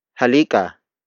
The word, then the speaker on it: halika: The first word is halika, halika, halika. Halika is spelled as H-A-L-I-K-A.